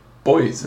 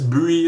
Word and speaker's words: buoy: Because we just call them boys in this country. – You're the racist now. – What? I just think the way he pronounces buoy is very impressive. We don't say buoy here. – We say boys.